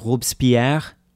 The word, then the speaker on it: Robespierre: So there's a guy named Maximilien Robespierre. – Ah, yes. That's also how I pronounce it.